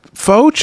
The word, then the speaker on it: faux: Faux Chucks, thank you. – And that's spelled what? How do you spell faux? – F-A-U-X.